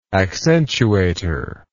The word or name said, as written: accentuator